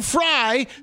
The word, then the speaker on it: Frey: Write it F-R-Y. It doesn't matter. The audience isn't seeing it spelled F-R-Y, but 100%. Write fucking Frey the way it's said.